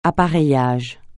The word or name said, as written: appareillage